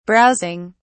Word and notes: browsing: British English

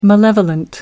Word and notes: malevolent: American English

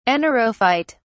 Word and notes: anaerophyte: British English

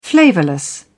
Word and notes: flavourless: British English

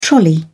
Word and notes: trolley: British English

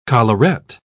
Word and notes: collarette: British English

